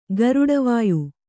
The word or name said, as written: ಗರುಡವಾಯು